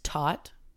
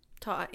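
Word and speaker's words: Todt: It's T-O-D-T, but I'm thinking that's Tot. – Tot, yeah.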